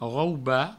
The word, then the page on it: raubar: rauba